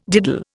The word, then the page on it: diddle: Indian